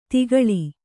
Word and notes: ತಗ್ಗಾಳು: Kannada

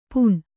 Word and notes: ಪೂಣ್: pūṇ

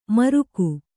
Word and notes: ಮಾರಕ: Kannada

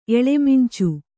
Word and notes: ಎಳಮಿಂಚು: eḷamiñcu

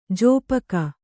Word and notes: ಜಾಪಕ: jāpaka

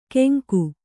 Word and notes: ಗೆಂಕು: Kannada

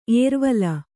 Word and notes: ಏರ್ವಲ: Kannada

